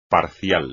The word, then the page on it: parcial: espagnol